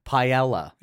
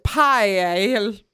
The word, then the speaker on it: paella: Sondra, who loves paella, pronounces it paella. – Paella. – Yeah, so Sondra, who knows how to cook paella. – Because she's Spanish.